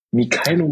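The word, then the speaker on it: Mykhailo: Der heißt mit Vornamen Mikhailo. Ich hoffe, ich habe es richtig ausgesprochen. Mikhailo Mudrik. Stimmt.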